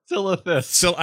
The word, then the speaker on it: Silithus: You'll travel to locations such as Storm Peaks, Syphilis, Oldwar. – Silithus. – I always say it wrong.